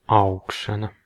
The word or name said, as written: growth